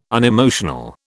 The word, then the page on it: unemotional: British English